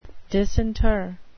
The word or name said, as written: disinter